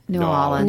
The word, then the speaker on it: New Orleans: And by the way, if you say New Orleans, you got to say it like that. New Orleans. – New Orleans.